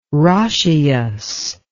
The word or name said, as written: roscius